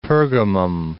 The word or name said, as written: Pergamum